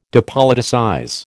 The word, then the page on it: depoliticize: American English